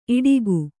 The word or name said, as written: ಈಡಿಗ